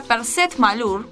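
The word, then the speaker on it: PCET-MALUR: que se pronuncia Perset Malur, así se llama, y surge por resolución del Consejo Ejecutivo también en el año 2008, precisamente en el mes de junio.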